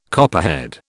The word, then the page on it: copperhead: British English